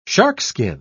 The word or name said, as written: sharkskin